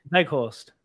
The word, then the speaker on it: Weghorst: And then, obviously, you can't ignore Weghorst, however you say his name. Weghorst. Is it Veg or Weg? – Weghorst. Veg. – Veg. Veg. – Veg. Yeah. Pronunciations Veg.